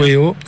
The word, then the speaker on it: Weo: Ça se prononce comme ça en américain. Weo, Weo en américain. Ah, Weo. Et Weo en français.